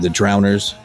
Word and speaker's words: Drowners: But Animal Nitrate, Downers, or the Drowners. Did I say that correct? No, not at all. – The Drowners. – Yep. I said it right the second time.